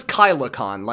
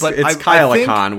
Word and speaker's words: KyleCon: But he says KylaCon, like it had to have an extra syllable in there, which it didn't. And I hate him. – It's KyleCon, where all the Kyle-aholics go.